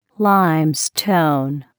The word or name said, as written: limestone